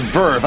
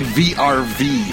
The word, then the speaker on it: VRV: And listeners, if you're interested in checking out Overlord, you can watch it in Crunchyroll, Funimation, and apparently it's pronounced Verve. I've been saying VRV for, like, the past 2 years.